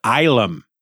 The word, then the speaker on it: Ylum: a lunar refuge named Ilum. It's spelled Y-L-U-M, and it's apparently a shortening of the word asylum.